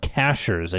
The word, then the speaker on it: Cashiers: It looks like Cashiers, but it's pronounced Cashers.